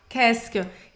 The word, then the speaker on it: qu'est-ce que: Você poderia pode, eh, pronunciar assim: qu'est-ce que? Qu'est-ce que? – Qu'est-ce que? E às vezes ele é até pronunciado simplesmente assim: qu'est-ce que? – Qu'est-ce que? – Qu'est-ce que? Então, esse negócio todo, esse mondrango todo, esse trem aí de letras todo para você falar Caskel.